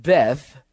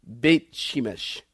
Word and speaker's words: Beit Shemesh: And they don't pronounce it Beth like it's written here. They pronounce it Beit. Beit. Beit Shemesh.